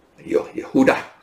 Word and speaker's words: Yehuda: Now, so this person, his real name was Judas or Judah, Yehuda, as the Hebrews would pronounce, but I think they put as Jude because they don't want to people to think this is the Judas Iscariot, right?